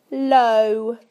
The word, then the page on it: low: British English